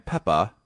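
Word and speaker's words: Pepa: And yes, it's not a Massachusetts accent. It's pronounced Peppa, P-E-P-P-A. – Thank you for that clarification. – It's actually just, this is. – It's just one P, P-E-P-A. – Peppa. – Yeah, you're right, one P.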